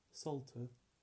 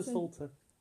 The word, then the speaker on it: Psalter: And he did also the first 50 Psalms of the Psalter, that's spelled like how you'd say spell Psalms, but like P-S-A-L-T-E-R. – Ah, but you Psalter. – Oh, okay. Psalter.